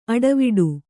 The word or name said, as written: ಅಡಿವಿಡಿ